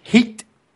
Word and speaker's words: heat: With eat. – With heat. Carlito, there's an H. You have to pronounce it.